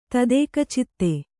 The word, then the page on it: ತದೇಕಚಿತ್ತ: tadēkacitta; kannada